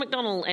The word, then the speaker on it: McDonnell: Not McDonald. – Oh, you come over here, you take McDonald, over our panel shows, you can't pronounce our names. – I'm so sorry. John McDonnell,